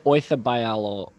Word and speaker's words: Oyarzabal: Oyarzabal. – I think it's more like Oyarzabal.